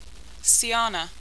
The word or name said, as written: Siyana